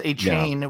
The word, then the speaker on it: Achane: We have to relearn it because Achane was perfect. Now it's Achane. And I just think like 4chan. – Yeah. – That's the only way I can think of it.